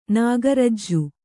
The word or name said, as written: ನಾಗರಜ್ಜು